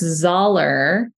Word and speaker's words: Zoeller: So, it's Zoeller. So, think dollar bill, but with a Z.